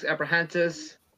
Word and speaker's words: Abrahantes: accompanied by Alex Abrahantes.